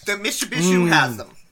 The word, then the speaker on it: Mishipeshu: How do you say it again? – Mishipeshu.